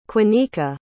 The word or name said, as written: quanika